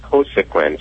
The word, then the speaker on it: Cosequin: And then we have her on, I believe it's pronounced Cosequin, C-O-S-E-Q-U-I-N.